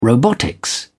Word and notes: robotics: British English

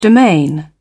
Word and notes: domain: British English

